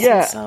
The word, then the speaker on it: Epsom: Their white powder granules or crystals often resemble Epsom. Is that how you say it? Epsom salts? – Epsom salts.